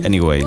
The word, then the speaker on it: Anyway: Anyway, ¿no? ¿Lo he dicho bien? – Sí, sí, sí, has dicho bien.